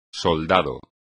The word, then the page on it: soldado: espagnol